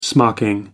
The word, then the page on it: smocking: American English